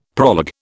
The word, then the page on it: proleg: British English